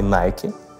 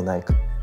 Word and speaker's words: Nike: Фил Найт, продавец обуви, книга от основателя компании Nike. Э, так, кстати, произносится правильно, а не Nike.